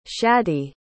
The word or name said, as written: shaddie